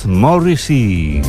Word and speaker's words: Morrissey: Por eso ha creado este número musical llamado Morrissey, o como se pronuncia en inglés, Morrissey.